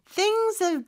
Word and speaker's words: things: Things의 마지막 S는 Z 발음이거든요. 그래서 그렇게 Z 발음 내면서 things have 이렇게 발음하면 되고요. 그럴 때는 H가 묵음이 돼요.